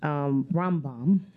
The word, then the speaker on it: Rambam: He's known by an acronym because his name is so hard to pronounce. – I saw that. – R-A-M-B-A-M. He's called the Rambam.